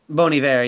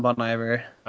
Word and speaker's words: Bon Iver: Oh, that's how you say it? – Bon Iver, yeah, it's not Bon Iver.